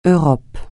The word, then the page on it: Europe: francais